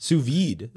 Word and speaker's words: vide: It's pronounced V-E-E-D. So it's vide. It's V-I-D-E in American, but it's pronounced vide. Sous vide.